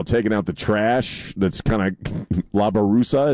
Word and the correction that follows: basura: is it? Did I say that correctly? – And, basura. – Basura. What's that? – Basura. – La basura. Okay.